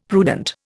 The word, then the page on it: prudent: Indian